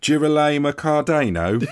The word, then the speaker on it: Girolamo Cardano: Sorry, can you say his name again? Because you've made him sound like he's a sort of East London mechanic. – Girolamo Cardano. – It's beautiful in your accent.